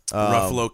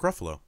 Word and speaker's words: Ruffalo: Ruffalo killing it. – What? – Mark Ruffalo.